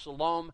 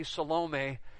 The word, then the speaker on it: Salome: When the Sabbath was over, Mary Magdalene and Mary the mother of James and Salome, and in our translations in English, it would seem to be pronounced Salome. In the Greek text, it would be Salome. And so maybe either way is okay.